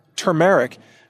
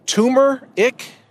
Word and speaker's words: turmeric: And by the way, it is pronounced turmeric. Please don't pronounce it turmeric. People like to say it's a tumeric, which isn't really how it's spelled. It's spelled T-U-R-M-E-R-I-C, turmeric or turmeric, but not turmeric. So just that clarification. I hear a lot of people mispronounce this word. I know it's kind of a crazy word, so just try turmeric, with the emphasis on the second syllable, turmeric.